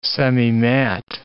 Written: semimat

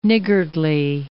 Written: niggardly

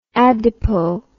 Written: adipo

